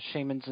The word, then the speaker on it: shaman: I was given a few cultural gifts by someone, which had me asking them about shamans in those parts. Their pronunciation was shaman, with the stress on the second syllable, and both syllables with a soft A.